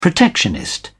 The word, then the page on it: protectionist: British English